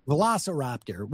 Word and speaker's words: Velociraptor: Two lucky winners will get to choose between, I can't pronounce these things, a Lamborghini Huracan Sturato or a Ford Hennessy Velociraptor. – Velociraptor, which is a, thank you, which is a souped-up Ford Bronco, or $100,000 in cash.